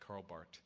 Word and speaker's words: Karl Barth: The last one is the relational view, and this begins about a century ago with a German thinker by the name of Karl Barth, with a little silent H at the end of that, Karl Barth.